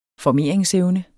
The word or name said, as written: formeringsevne